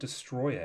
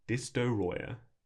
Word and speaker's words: Destoroyah: But it is spelt with multiple O's. And I think it's Destoroyah? Destoroyah? – Destoroyah.